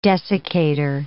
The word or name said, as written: desiccator